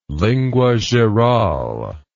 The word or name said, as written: lingua geral